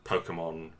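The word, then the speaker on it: Pokémon: Is that how it's pronounced? Pokemon. Yes. – Pokemon. Little fellas in the balls.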